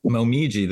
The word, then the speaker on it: Momiji: Was it Momiji? – Momiji. That's Momiji. – Yeah. – That's Momiji. Maybe. Yeah. Sorry. – Okay. So that's just the actual Japanese pronunciation for it.